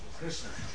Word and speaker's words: Krishna: So if you think of a sh sound, and then think of an N, which is made in the same place in your mouth as a sh, Krishna.